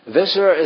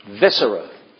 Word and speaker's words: viscera: Viscera is a fancy word for guts. Viscera. That's a good word. V-I-S-C-E-R-A. Yes, it's not pronounced viscera. Viscera.